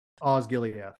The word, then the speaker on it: Osgiliath: Osgiliath.